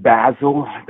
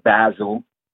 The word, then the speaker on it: Basil: It's St. Basil. – you were saying it like the spice basil. – Yeah, I was saying it like we learned it in grad school, bro. Like Saint Basil, right next to Saint Paprika and Saint Cayenne.